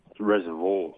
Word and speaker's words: Reservoir: Reservoir.